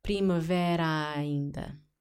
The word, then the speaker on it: primavera ainda: So, two A's. Primavera is ending with an A. Ainda is starting with an A. So, pronounce one A as if it had an accent. Primavera ainda ok?